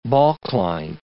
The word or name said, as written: balkline